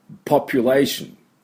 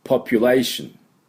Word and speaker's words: population: で、だからこの音が出てないと、ポピュレーションでは通じないということです。ポピュレーション、ポピュレーション。はい、これが問題、大事なところです。で、じゃあ私の後について発音しましょう。ポピュレーション、ポピュレーション。